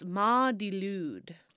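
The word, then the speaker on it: Mari Lwyd: And it's called, I wrote how it's pronounced. It's pronounced Ma di Lwyd.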